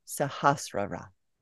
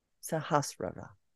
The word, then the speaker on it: Sahasrara: Going forward, I will use the correct pronunciation, Sahasrara. Sahasrara. Sahasrara is energy at the highest vibration, which is beyond sound and light and into ether and thought waves.